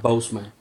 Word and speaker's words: Boseman: Boseman, Boseman, ¿verdad? Que se pronuncia la. – Ajá, Boseman.